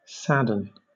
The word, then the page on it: sadden: British English